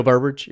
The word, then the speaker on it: Burbridge: And what they did was they hired four guys from Sun Ra, big band, John Medeski, myself, Mark Rebo, O'Teal, the bassist, Budridge. That's how you pronounce his name, I think. – O'Teal Burbridge, yeah.